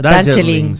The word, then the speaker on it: Darjeeling: Sí, jo viaje a Darjeeling, oi la pronunciació, Darjeeling, sí.